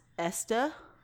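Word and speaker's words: Estée: Estee Lauder is pronounced Esta.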